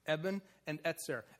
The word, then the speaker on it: Ebenezer: We usually pronounce it as a Z. Eben and Etzer.